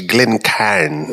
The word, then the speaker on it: Glencairn: And so, you know, is it Glencairn, C-A-R-I-N, or is it Glencairn, C-A-I-R-N? I see it all the... – Well, it's Irish, so it's Glencairn, C-A-I-R-N.